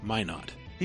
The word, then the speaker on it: Minot: Also, Minot, North Dakota is pronounced Minot.